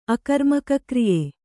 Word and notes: ಅಕರ್ಮಕಕ್ರಿಯೆ: akarmakakriye

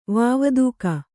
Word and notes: ವಾವದೂಕ: vāvadūka

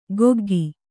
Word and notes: ಕೊಗ್ಗಿ: koggi

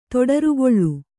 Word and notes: ತೊಡರುಗಾಲು: toḍargālu

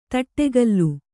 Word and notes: ದಡಗಲಿ: daḍagali

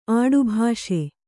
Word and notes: ಆಡುಭಾಷೆ: Kannada

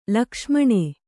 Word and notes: ಲಕ್ಷ್ಮಣ: Kannada; lakṣmaṇa

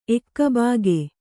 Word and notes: ಏಕಭೋಗ: ēkabhōga

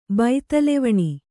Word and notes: ಬೈತಲೆವಣಿ: baitalevaṇi

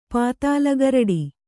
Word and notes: ಪಾತಾಲಗರಡಿ: pātāla garaḍi; Kannada

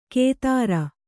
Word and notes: ಕೇದಾರ: kēdāra

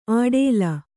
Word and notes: ಆಡೇಲ: āḍēla

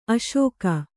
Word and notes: ಅಸಗ: Kannada

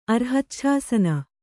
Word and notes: ಅರ್ಹಚ್ಛಾಸನ: arhacchāsana

